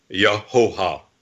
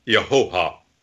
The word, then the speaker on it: Yehovah: And that name has three syllables. It has to have three syllables. There's no other way to perceive it. And it has to be Yehovah. Yehovah.